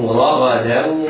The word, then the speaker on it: رغدا: حيث شئتم راغدا، إياك وأن يجعل تفخيم يؤدي تفخيم الراء والغين إلى تفخيم الدال فتصبح ضادا أو قريبة من الضاد. لا، احرص على ترقيق الدال بعد الحرفين المفخمين، بعد الراء والغين المفخمتين، فتنطقها كذا، انطق هكذا، حيث شئتم راغدا وراغدا دا دا، حيث شئتم رغدا وادخلوا، رغدا وادخلوا، تنوين بعده واو حكمه الإدغام بغنة.